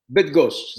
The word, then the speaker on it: Bydgoszcz: Zawisza Bydgoszcz. – Bydgoszcz. Zawisza Bydgoszcz.